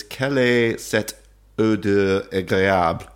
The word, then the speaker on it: Quelle est cette odeur agréable: So the original is Quelle est cette odeur agréable? – Okay. Beautifully pronounced.